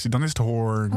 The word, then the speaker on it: Hoorn: Maar dus wel in Hoorn. – In Hoorn. Ja, is het... Ik weet dat nooit. Is het Hoorn? of horen, hoe spreek je dat uit? In Hoorn zeggen ze hoorn.